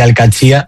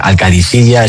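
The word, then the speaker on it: Al-Qadsiah: Bueno, se suele decir Al-Katzia, pero también hay gente que dice Al-Kadisiyya. Es que es difícil, ¿eh? Ah. No es fácil, pero Al-Katzia es lo más fácil.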